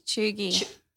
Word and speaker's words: cheugy: Choogy. – Spell it. – C-H-E-U-G-Y.